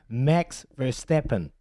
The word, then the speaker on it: Max Verstappen: Max Verstappen.